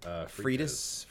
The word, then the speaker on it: Freitas: Freitas. – Freitas. – Freitas. – Freitas. – Freitas. – You put a little roll in the R if you want.